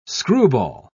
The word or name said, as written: screwball